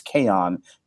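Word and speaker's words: Chaon: I believe it's Kahan. It's spelled like chaos, but with an N instead of an S.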